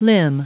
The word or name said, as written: limn